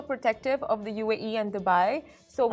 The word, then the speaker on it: Dubai: and it's like when people don't even know how to say Dubai, and they go like Dubai. It's like oh my god. – That is how you say it. Dubai.